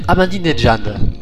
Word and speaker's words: Ahmadinejad: Il s'appelle Ahmadinejad. – D'accord. Ça, très très bonne prononciation, j'adore.